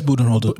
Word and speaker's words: Budenholzer: You know, and like, I was gonna say, and last night that adjustment, I was stunned that Budenholzer, I feel like I'm always pronouncing his last name right, the Bucks head coach. – Yeah, it's Budenholzer.